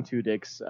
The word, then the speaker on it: Tudyk: How do you say that guy's last name? Tudyk? – Tudyk. – Alan Tudyk. – Tudyk. – Firefly, baby. – Like two penises? Like, that's how it's pronounced, for real? – Tudyk.